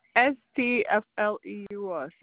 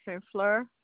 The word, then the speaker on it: St. Fleur: Oh, STFLEUR, St. Fleur.